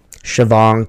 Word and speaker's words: Shivang: Shivang Tallapaka.